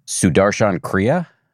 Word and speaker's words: Sudarshan Kriya: Sudarshan Kriya, I believe.